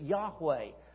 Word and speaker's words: Yahweh: Jehovah is just a more Latin pronunciation, but Yahweh. In Hebrew, there are no vowels, and so you'll often see it just Y-H-W-H.